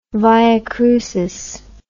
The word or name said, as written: via crucis